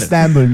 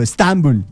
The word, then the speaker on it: Estambul: A ver. Estambul. Nosotros le ponemos el acento en otro lado. Estambul. Así le decimos nosotros, los más cercanos a esa tierra.